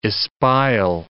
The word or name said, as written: espial